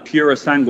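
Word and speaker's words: Purosangue: The Pura Sangue. Mm. I think that's exactly the way to pronounce it, which is Italian for thoroughbred.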